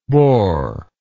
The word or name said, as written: borable